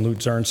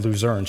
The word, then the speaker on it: Luzern: Becky, Sam's wife, spent 3 months in Luzern, so when I said Luzern, she corrected me. That's how you say it, Luzern.